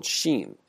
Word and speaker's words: xin: It's called xin. It's spelled X-I-N.